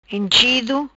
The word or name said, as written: Inchido